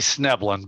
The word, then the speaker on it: Schnoebelen: We just say Schnoebelen, but you're doing better than most people do.